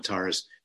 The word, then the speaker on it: Tara: But in the form, of course, of the 21 Taras, she can also be very fierce in terms of what she, of removing suffering states.